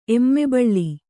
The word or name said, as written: ಎಮ್ಮೆಬಳ್ಳಿ